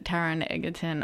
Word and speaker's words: Taron Egerton: So, Charlize Theron and Taron Egerton. Oh my gosh. I cannot pronounce anything live on air right now. – What are you saying? You got it right. – Hey? – You said it right.